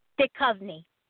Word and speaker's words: Duchovny: I just love David Duchovny. How do you say it? David Duchovny or Duchovny? I've used his picture for years. – Duchovny. – Duchovny. – Duchovny. Yeah. – Okay, thank you.